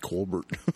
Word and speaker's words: Colbert: Colbert. – Accent on the wrong syllable.